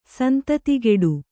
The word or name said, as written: ಸಂತತಿಗೆಡು